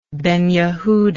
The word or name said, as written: Ben Yehudah